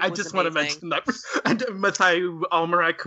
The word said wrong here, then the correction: Amalric: or however you say his name. I was like, yes. Oh my God. – Yes. Isn't it just Matthew Amalric? – Sure. – Amalric.